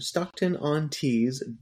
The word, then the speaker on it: Stockton-on-Tees: Located all of the way over in Stockton-on-Tees, Durham, England, United Kingdom.